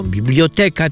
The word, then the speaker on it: biblioteka: Z biblioteką jest, powiedziałbym, sprawa bardziej skomplikowana. Niektórzy mówią biblioteka, inni biblioteka. Sam skłaniam się do tych już, którzy mówią biblioteka, tak jak kinoteka, dyskoteka, nie dyskoteka, prawda?